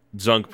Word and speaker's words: Zunk: Uh, Zunk pronounced like junk.